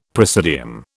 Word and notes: praesidium: British English

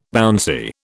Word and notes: bouncy: British English

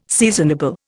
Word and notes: seasonable: Indian

